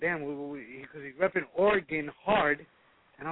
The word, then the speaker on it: Oregon: From Oregon.